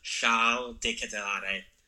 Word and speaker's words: Charles de Ketelaere: We're getting some players back from loan, but a young Belgian like attacking mid, Charles de Ketelaere, but I believe it's pronounced Charles de Ketelaere in Belgian.